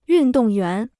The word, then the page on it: 运动员: yùn dòng yuán